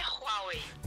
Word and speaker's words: Huawei: Então, uma das marcas assim que as pessoas falam errado, e eu não sei quem começou com isso, e eles falam com muita certeza, é a Huawei. – Uhum. – A pronúncia mesmo é Huawei.